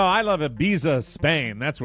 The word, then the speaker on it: Ibiza: This is according to Ibiza. – It's Ibiza. – Oh, he's using the Castilian pronunciation.